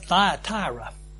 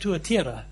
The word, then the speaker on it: Thyatira: And so if you're like me from Texas and you heard a sermon on this church, they would call it Thyatira. That's said in proper Texan accent, Thyatira. Well, that's not anywhere close to what it was in the original, but that's how people in Texas say it. And if you're German, you would probably say it Tuatira, right? That's how the ordinary German would pronounce it, Tuatira.